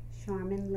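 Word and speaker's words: Sharmyn: This is Christy Frazier, and I'm here with Charmin Lilly.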